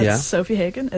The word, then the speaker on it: Sofie Hagen: I'm on Twitter at Sofie Hagen. S-O-F-I-E-H-A-G-E-N. – You know you said that wrong. – I didn't. – Hagen. Yeah, you said Sofie Hagen or Hagen. What did you say? – Oh, okay.